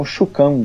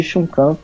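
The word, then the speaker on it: Chuxing: É, o final Xing, na verdade, se pronuncia Kan. Então, Chukan, Didi Chukan, ela é uma empresa chinesa e que ela comprou a 99 pelo valor de $1 bilhão.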